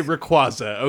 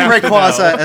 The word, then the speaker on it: Rayquaza: That's How do you pronounce Rayquaza? I would say Rayquaza. Okay. – Okay, I say Rayquaza as well.